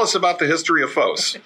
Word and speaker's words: FOS: P-H-O with the long O. – Long O. FOS is the Greek word for light.